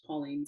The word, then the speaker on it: Paulene: Or you can find me on Facebook, Pauline Scanlon. – How do they spell it? – My first name is P-A-U-L-E-N-E, not I.